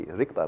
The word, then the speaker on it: Rigpa: it's so easy, Rigpa, like peanut, cherry, jelly, Rigpa. Just as easy to say as any other two-syllable word.